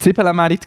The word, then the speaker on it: Zibelenmerit: Äh, es war Zibelenmerit. Wichtig, die Betonung liegt auf Zibelen, nicht Zwiebeln oder Zibeln oder sonst irgendein Scheissdreck.